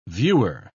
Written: viewer

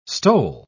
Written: stole

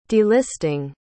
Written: delisting

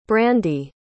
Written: brandie

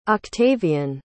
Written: octavian

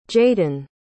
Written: jaeden

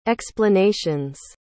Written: explanations

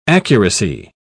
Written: accuracy

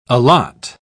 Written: allot

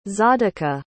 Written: tzadika

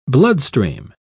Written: bloodstream

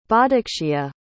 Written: bodiccea

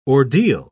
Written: ordeal

